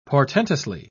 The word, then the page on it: portentously: British English